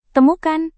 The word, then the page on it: temukan: indonesian